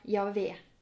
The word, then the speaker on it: Javé: J, H, V, H. Eller Javé, som man tror at man skal uttale dette navnet som.